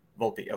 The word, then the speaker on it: Volpe: The I think him going back to second, and with Volpe, how do you pronounce it again? – Volpe.